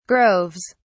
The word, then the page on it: groves: British English